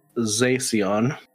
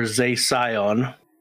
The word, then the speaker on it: Zacian: And the reason why I say it's pronounced Zacian or Zacian is because it is derived from the word Scion, as in the car that nobody wants to drive.